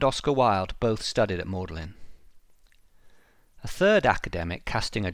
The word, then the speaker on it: Magdalen: It's written as Magdalen, but pronounced Magdalen.